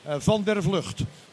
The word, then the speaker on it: Van der Vlucht: You want the American pronunciation or the Dutch one? – The Dutch. – Van der Vlucht.